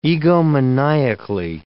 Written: egomaniacally

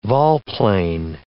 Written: volplane

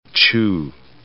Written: Chu

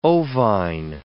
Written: ovine

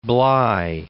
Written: Bligh

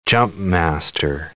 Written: jumpmaster

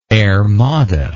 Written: airmada

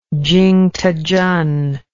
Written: chingtechen

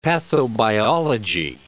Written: pathobiology

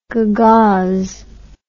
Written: gagauz

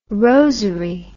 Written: rosery

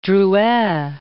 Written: Drouet

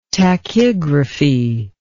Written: tachygraphy

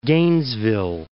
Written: Gainesville

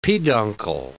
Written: peduncle

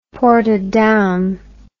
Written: portadown

